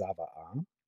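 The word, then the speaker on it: tzava'ah: And the first one is based on a concept in Judaism called the ethical will, which in Hebrew is pronounced zava'ah.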